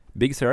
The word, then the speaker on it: Big Sur: Watch OS 7 og Mac OS Big Sur, eller hvordan uttaler jeg det? – Big Sur, ja. – Big Sur? – Ja.